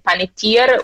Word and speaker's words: Panettiere: Esatto, una notizia arrivata nella notte: è morta a 36 anni Aiden Panettiere, uso la pronuncia americana, sì.